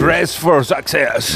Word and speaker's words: Dressed for Success: La verdad es que es difícil de pronunciar esto, ¿eh? Hazlo tú, que eres más inglés. – Dressed for Success. – ¿Ves? A que le sale mucho mejor.